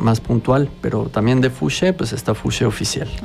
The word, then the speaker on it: Fouché: También, si gustan escuchar la música en plataformas de streaming, están todas esas, busquen la palabra Fouché, pero con acento en la E para que lo encuentren. – Fouché.